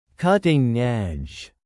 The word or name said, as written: cutting-edge